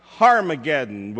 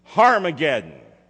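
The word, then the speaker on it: Harmagedon: And the Word of God refers to this campaign, and in the Hebrew it's pronounced Harmageddon with an H sound. Harmageddon. And the word Harmageddon is made up of two words, Har, H-A-R,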